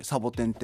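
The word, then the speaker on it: 仙人掌: 漢字で書くと「千人の手のひら」って書いてサボテンって読むんですけどその手のひらっていうのはそのうちわサボテンのあの平べったいのがちょっと。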